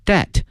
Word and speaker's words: debt: Lee now has paid back most of his debt，债务这个词它的拼写呢是 D-E-B-T，但是啊有一点啊，在考试的时候特别在变音的时候经常会考大家这个 B 啊，这个 B 呢是不发音的，它的发音呢是 that，但是呢拼写里面会有这个字母 b，所以同学们一定要小心了。